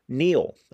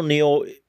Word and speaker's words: Kneale: But that theory is called the Stone Tape theory mostly because of this British show by Nigel Neal. I might be saying that wrong. K-N-E-A-L-E.